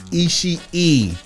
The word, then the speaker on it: Ishii: Tomohiro Ishii. There are two I's. You pronounce both of them on the end, that is. Three I's in the name. Tomohiro Ishii. Not Ishii. Ishii. – Thank you. I appreciate you. Continue. – WYBA. Anyway. – P-A-F-N.